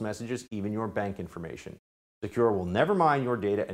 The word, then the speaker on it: Sekur: That's why you need a new privacy and cybersecurity application tool called Secure. It's spelled S-E-K-U-R. Secure is using proprietary encryption and offering secure instant messaging and email.